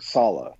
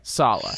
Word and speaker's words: Saleh: So I am terrible when it comes to names, but I'm pretty sure after years of fucking it up, it is Robert Saleh. – Okay. Saleh.